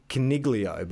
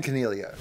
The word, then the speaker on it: Coniglio: Um, uh, okay, now this is tricky because it's because people say Coniglio, but I believe the G is silent. I believe it is Stephen Coniglio. Coniglio. Stephen Coniglio. Hi guys, the correct way to pronounce my name is Stephen Coniglio, not Coniglio. Uh, it's a silent G.